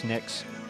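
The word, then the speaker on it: Knyx: It's spelled K N Y X. I think that's Nix.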